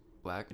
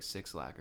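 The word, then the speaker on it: 6LACK: His way you pronounce his name is just Black. – Just Black. – I thought it was like Six Lack or something. – No, it's just Black. Yeah.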